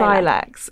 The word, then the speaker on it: Cilex: Silex is the short form, but it's spelled C-I-L-E-X.